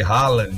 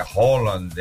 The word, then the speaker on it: Haaland: com Mbappé, Haaland, né, que eu descobri agora, né, que todo mundo pronuncia errado o nome de Haaland, né, porque é Haaland, ele mesmo falou que o nome dele é Haaland,